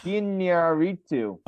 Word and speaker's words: Iñárritu: Iñárritu.